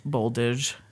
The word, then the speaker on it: Boldizs: A Z and an S together are pronounced like a zh sound. – Oh. – So his last name is Boldage.